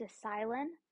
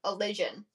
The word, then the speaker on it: Elysian: According to a quick Google search, Elysian, which sounds like collision.